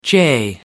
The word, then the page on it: jay: American English